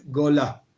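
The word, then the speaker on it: gola (גולה): En hebreo se dice gola, goila.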